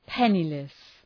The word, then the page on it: penniless: ‘penılıs